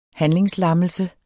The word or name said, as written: handlingslammelse